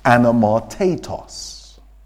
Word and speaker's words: anamartetos: And that Greek word is pronounced somewhere around the terms of anamartatos.